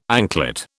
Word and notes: anklet: British English

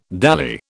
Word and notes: dally: British English

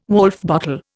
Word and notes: Woulfe bottle: Indian

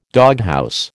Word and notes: doghouse: American English

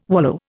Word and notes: wallow: Indian

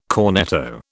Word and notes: cornetto: British English

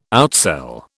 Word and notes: outsell: British English

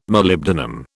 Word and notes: Molybdenum: British English

